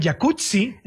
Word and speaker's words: Jacuzzi: Oye, así que el jacuzzi. – Jacuzzi. – Entonces deberíamos pronunciar el jacuzzi. – Exacto. – Como pizza. Como Rampazzo, sí, como Claudia Rampazzo.